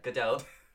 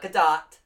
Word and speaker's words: Gadot: Yeah, Gadot. It's not Gadot. – NPR? – Yeah. – You hear? – Please stop. Just pretend there's an E at the end of it, and you'll be able to pronounce it.